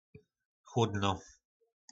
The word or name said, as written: chlodno